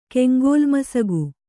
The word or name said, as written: ಕೆಂಗೋಲ್ಮಸಗು